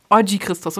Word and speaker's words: Oggi Christos: Wie spricht man deinen Künstlernamen richtig aus? Also ähm, einfach Oggi Christos. Oggi Christos, okay.